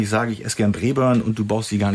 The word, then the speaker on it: Braeburn: Ja, Braeburn ist schon richtig ausgesprochen.